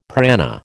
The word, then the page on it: prana: American English